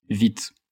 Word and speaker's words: Vite: So the proper pronunciation is Vite. So it's pretty much just the same, but the E is shorter, Vite.